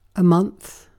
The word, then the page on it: month: British English